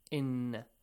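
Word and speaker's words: inna (إنّ): Hamza e has to be pronounced correctly. Do not get it wrong, okay? It's not ayin sound. E, inna. Exactly like the letter A in English, or the letter E, yes? Or the letter I. – Yes. – Ink, an, English. So they start with e sound.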